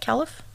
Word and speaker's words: caliph: Am I saying that right, caliph? – Yeah.